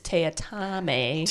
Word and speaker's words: Teatime: The task is given to Mr. Tea Time, which is a mispronunciation. It's Teatame.